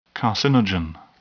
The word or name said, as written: carcinogen